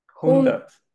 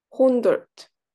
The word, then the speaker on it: Hundert: Hundert. – Hundert. – Hundert. Yes. Yeah. It's like hundred, just yeah, with a very German sound.